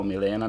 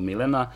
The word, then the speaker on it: Milena: Meni je u tom trenutku zasjala informacija o dva prezimena, od kojih je jedno italijansko, ime koje se ne izgovara kao Milena nego Milena, ali isto tako i ono što sam imao priliku da vidim kao reprodukcije njenih umetničkih dela.